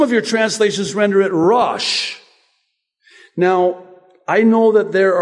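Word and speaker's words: Rosh (ראש): In my native language of Arabic, we say Ras. In Hebrew, it's Rosh, like Jesus in Arabic is Yeshua, and in Hebrew, it's Yeshua. So they pronounce the sh, and we pronounce with the s.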